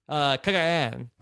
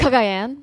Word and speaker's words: Cagayan: And then I believe the 3 weeks from tonight, the Tuesday before the premiere of Survivor Cagayan. I've been told that's the proper pronunciation. – Cagayan.